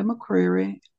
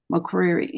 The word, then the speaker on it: McCreary: Yes, my YouTube channel is up under my name, Shawanda McCreary. In parentheses, it have Mother's Love. My name is spelled Suhwanda McCreary, MCCREARY.